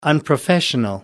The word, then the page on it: unprofessional: American English